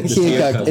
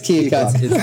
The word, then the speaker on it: Keokuk: Two or three times we played at this venue up in Iowa, Keokuk. Am I saying that right? – Keokuk. It's Keokuk.